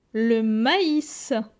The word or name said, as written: Le mais